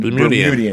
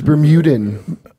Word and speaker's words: Bermudian: Pronounce that, Bermudian? – Bermudian. – Because that's been bothering me for a long time. – Really? – I thought it was Bermudan. – No, that's that. Yeah, that's not.